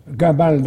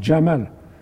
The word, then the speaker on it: Jamal: Et les Anglais ont eu la prononciation égyptienne, au lieu du j, ils mettent g. Comme il disait Gamal Nasser, tous les Arabes disent Jamal.